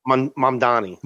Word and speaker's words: Mamdani: Mamdani.